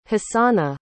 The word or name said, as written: hasana